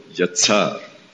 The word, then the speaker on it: yetzar: Or actually, the intonation is in the last syllable, yetzar.